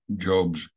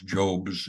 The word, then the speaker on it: Job: As I use a digital voice to go through this, you're going to find out he doesn't pronounce Job's name as Job. He pronounces it Job. And that's the training of the voice.